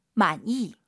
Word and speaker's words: 满意: 만이. – 많이 많이 니은 발음 들리면 안 돼요. 이응 많이 많이 이렇게 발음하시기 바랍니다. 매우 만족스럽다.